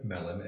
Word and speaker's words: Mellen: Chances are, if you spell my name wrong, you'll still find me, but it's Jim Mellon, M-E-L-L-E-N.